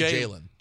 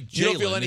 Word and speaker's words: Jalen: So you don't feel, when you say Jalen, it's one syllable, right? The way he said Jalen. – No, it's still two syllables. But it's rushed.